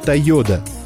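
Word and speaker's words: Toyoda: Фамилия основателей звучит как «Тойода». Для упрощения произношения и в качестве символа отделения деловой деятельности от семейной жизни компании было решено дать имя «Тойота».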